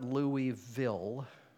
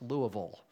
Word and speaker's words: Louisville: And that's the way you pronounce it, by the way. It's not Louisville. It's Louisville. Don't tell me, don't ask me why, but that's what it is.